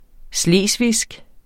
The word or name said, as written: slesvigsk